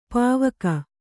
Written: ಪೌವಗೆ

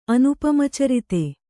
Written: ಅನುಪಮಚರಿತ

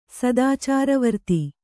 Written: ಸದಾಚಾರವರ್ತಿ

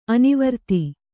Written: ಅನಾವರ್ತ

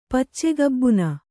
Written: ಪಚ್ಚೆಗಬ್ಬುನ

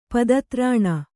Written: ಪದತ್ರಾಣ

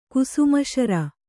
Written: ಕುಸುಮಶರ

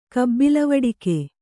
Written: ಕಬ್ಬಿಲವಡಿಕೆ

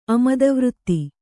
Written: ಅಮದವೃತ್ತಿ

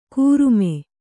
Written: ಕೂರುಮೆ

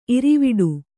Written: ಇರ್ವಡಿ

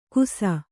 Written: ಗುಸ

